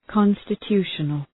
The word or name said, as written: constitutional